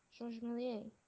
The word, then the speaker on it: Georges Méliès: George Millier? – Georges Méliès.